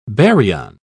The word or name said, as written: baryon